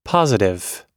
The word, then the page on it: positive: adjective